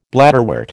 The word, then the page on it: bladderwort: American English